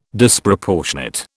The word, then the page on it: Disproportionate: British English